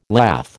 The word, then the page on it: lath: American English